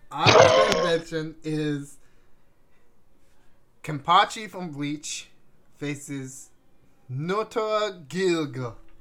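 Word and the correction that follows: Nnoitora Gilga: Did I say that right? – My man really just put another hand on his sword. Yes, Noitora Gilga. That's right.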